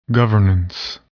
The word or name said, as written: governance